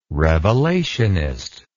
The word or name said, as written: revelationist